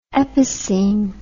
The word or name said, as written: epicoene